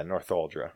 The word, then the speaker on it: Northuldra: isn't it? Nathaldra? – Nathaldra? – Yeah, isn't that how you say it? Northuldra? – Yeah, Northuldra. – Northuldra.